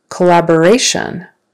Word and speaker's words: collaboration: Change that to collaboration. Now it's on the next to the last syllable.